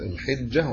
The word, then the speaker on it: الحجة: الأشهر الحرم هي ذو القعدة وذو الحجة أو الحجة والمحرم ورجب هذه الأربعة هي الأشهر الحرم يعني ذو القعدة بنطقها الحجة والحجة كلاهما صحيح القعدة دي ما فيش غير ذو القعدة.